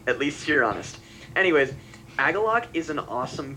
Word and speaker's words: Agalloch: And I pronounce it Agalosh. But I believe it now that it is Agalloch.